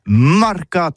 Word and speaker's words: marcats: Eh bien, disais à tous, mais le mercat de l'Uni ou le mercat des rebelles, mais en Gascogne toulousano, c'est prononcé sur les marcats, avec un A. Marque. En Gascogne toulousaine, bah, du côté, par exemple, de l'île des Baches, c'est l'île Jourdain, pour parler du marché, vous prononcez les marcats.